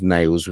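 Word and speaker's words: Nailz: Appeared in Paul Amperstein's American Wrestling Federation as Nails, pronounced Nails with a Z, alongside many WWF wrestlers from the 80s and 90s.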